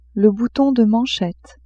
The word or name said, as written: bouton de manchette